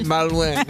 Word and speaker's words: malouin: Tu pensais me lancer Il a pas dit je suis pas loin, il a mal prononcé. – C'est malouin.